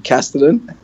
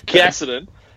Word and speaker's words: Casterton: No, it's had two jump outs at I watched them at Casterton. How do you say that? – Casterton. – At Casterton.